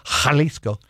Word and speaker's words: Jalisco: J's or H's, just remember. – J's or Jalisco.